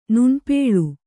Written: ನುಣ್ಪೇಳು

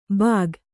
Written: ಬಾಗ್

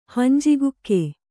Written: ಹಂಜಿಗುಕ್ಕೆ

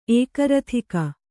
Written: ಏಕರಥಿಕ

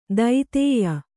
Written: ದೈತೇಯ

